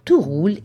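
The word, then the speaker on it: Two Roule: Tout Roule est une start-up française qui fonce comme sur des roulettes. Mais attention, tout, ça s'écrit T-W-O comme deux.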